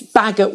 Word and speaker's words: Baggot: And we were laughing about my name earlier on because it's Baggot, which rhymes with maggot.